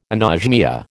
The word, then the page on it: anosmia: American English